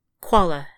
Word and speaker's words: Quala: "Quala," she says. Two syllables, not like the bear.